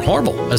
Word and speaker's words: Hormel: introduced by Hormel, or Hormel, as the locals say.